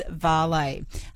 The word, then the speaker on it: vale: Looking up the ABC pronunciation guide, which is a public facing document, Paul, it is vale.